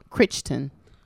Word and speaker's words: Crichton: So just that, and that's by Michael Crichton.